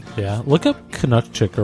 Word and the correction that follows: Kucinich: or however you say his name. I've never been able to say it. – Kucinich? – Kucinich. Dennis Kucinich.